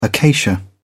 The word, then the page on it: acacia: British English